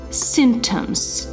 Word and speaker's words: symptoms: Обратите внимание, слово symptoms произносится без p symptoms, то есть пи я как бы э, букву не произношу.